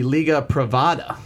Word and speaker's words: Liga Privada: I mean, you totally butchered the second word, but the Liga Privada.